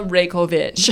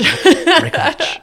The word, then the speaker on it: Raykovich: Dana Raykovich. Raykovich.